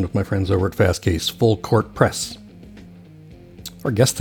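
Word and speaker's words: Fastcase: Did I say Fast Case? Did I say it correctly? I didn't. Fast Case.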